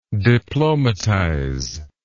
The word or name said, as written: diplomatize